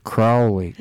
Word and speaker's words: Crowley: If you think Crowley is holy, you say Crowley, but Crowley is if you think he's Fowley. So you pronounce it Crowley, like grow, Crowley.